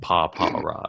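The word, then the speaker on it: Pawpaw Rod: It's it's P-A-W-P-A-W space R-O-D. So like Papa Rod.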